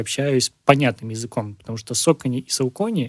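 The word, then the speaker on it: Saucony: Тема произношения — это на самом деле отдельная тема, потому что если прям совсем правильно, то да, это Nike, это Adidas, это Э, Сокони.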